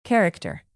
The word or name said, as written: character